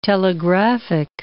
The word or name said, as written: telegraphic